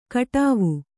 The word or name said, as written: ಕಡಾವು